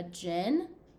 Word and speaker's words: djinn: And then Sam's like, well, it's actually like they're after a djinn. Djinn is kind of how they pronounce it in the thing.